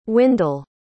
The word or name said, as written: Windell